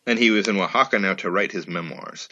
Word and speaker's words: Oaxaca: And he goes to, I don't know how to pronounce this place, O-A-X-A-C-A. How do you say that? – Oaxaca. – Oaxaca.